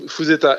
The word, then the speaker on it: Fuzeta: Ouais, Fuzeta, F-U-Z-E-T-A. – Voilà, tout simple à prononcer, comme ça se prononce.